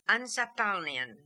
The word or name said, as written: Ansapalnian